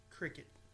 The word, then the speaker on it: Cricut: But no, I am going to learn to do the Cricut stuff. So is that how you say it? – What? – Cricket. – Yes. – Like the insect. – Yes. – Okay. – Like Jiminy Cricket.